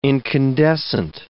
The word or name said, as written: incandescent